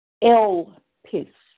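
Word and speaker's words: elpis (ἐλπίς): I want you to know, hallelujah, that I looked up the Greek word for hope, and it is pronounced elpis.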